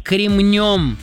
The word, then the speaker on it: кремнем: Ударение в нем всегда ставится только на последний слог, поэтому правильно говорить «кремнем», «кремнем».